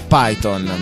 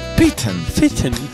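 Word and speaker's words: Piton: La pronuncia precisa non era il signor Python, ma era il signor Python. – Piten.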